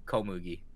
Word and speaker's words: Komugi: and her name reminds me, it rhymes with, like, wheat. Komugi.